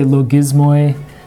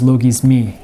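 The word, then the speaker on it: logismi (λογισμοί): These are, it's related to the word logos, but it's a word that specifically means, sometimes people say logismoi, but it's the Greek pronunciation is logismi. Like this is just an E sound here.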